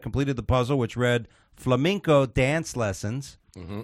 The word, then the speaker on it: flamenco: It's a flamenco dance lessons, but he pronounced it as flamingo, which led the host Pat Sajak to call him a dumbass.